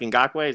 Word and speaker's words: Ngakoue: And Yannick Ngakoue, is that his name? That's how you say it? – Yeah. – Ngakoue? – Mm-hmm.